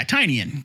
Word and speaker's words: Tynion: I understand that we have always been saying it wrong because Tynion the Four is just kind of funny to say. But yeah, Tynion. James Tynion the Fourth.